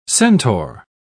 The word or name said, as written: centaur